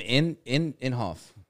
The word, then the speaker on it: Inhofe: Looks like Jim Inhofe. – Yeah, Jim Inhofe.